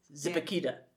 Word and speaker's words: Zipaquirá: Well, we went to the Salt Cathedral of Zipaquirá. Mm-hmm. Is that how you say it? – Zipaquirá. – Yeah. And that's right.